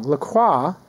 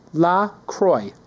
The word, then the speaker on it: La Croix: La Croix is pronounced La Croix, not La Croix, or La Croix, like French might say it. The company even explains on its website the name is pronounced La Croix, which is how you pronounce it, correct? – And it rhymes with enjoy.